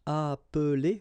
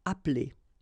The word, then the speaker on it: appeler: Okay, same thing here, appeler, but then you get appeler, appeler, and then samedi.